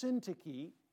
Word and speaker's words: Syntyche: In verse 2, I beseech Euodias, and it's pronounced Syntyche. I know it doesn't look like that, but that's how it's actually pronounced. I know we've had a lot of babies born in the church.